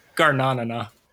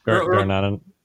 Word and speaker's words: Garnanana: It's kind of like you said it like banana, but you know, it's more like Garnanana. Garnanana.